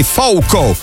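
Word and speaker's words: Falcão: Anche Ezio mi diceva: secondo me non si pronuncia proprio Falcao. In realtà, ascoltando il testo della canzone, mi sono messo, ho alzato al massimo le mie orecchie bioniche e io ho percepito un Falcao per renderlo più occidentale e Fouco invece come pronuncia portoghese e brasiliana.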